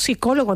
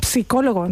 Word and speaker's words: psicólogo: La palabra psicólogo se escribe con una P inicial, pero esa P no la pronunciamos. – Es verdad. – Decimos psicólogo, no decimos psicólogo. No digan ustedes esa P inicial, que eso no se dice, eso es muy cursi.